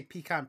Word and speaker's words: pecan: Pecan or pecan? How do you say it? – Well, I say pecan pie. Pecan. So it's pecan.